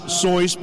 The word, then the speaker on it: Seuss: And it's very appropriate that it is actually pronounced Seuss because it rhymes with rejoice.